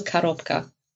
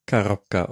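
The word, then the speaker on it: Korobka (коробка): Das stimmt, weil natürlich Russisch ein unbetontes O wird wie ein A gesprochen, also Korobka. – Korobka, okay.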